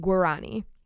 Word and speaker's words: Guarani: Guarani. That's how you say it, Guarani.